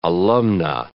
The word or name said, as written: alumna